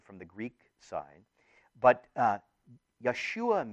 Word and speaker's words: Yeshua: Now, Jesus, we've talked about this before, but his name in Hebrew would be pronounced Yeshua. Yeshua. There is no J sound.